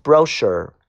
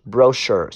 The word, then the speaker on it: brochure: 下个单词 brochure brochure ch 发 sh brochure 小册子注意这个单词的发音，读 brochure。